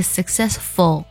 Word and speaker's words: successful: successful，我们在读的时候呢，也要注意不能将舌侧音读成儿化音，读作 successful，而是 successful，要这样去读它。